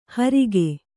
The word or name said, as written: ಹರಿಗೆ